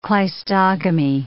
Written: cleistogamy

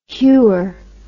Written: hewer

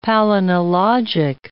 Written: palynologic

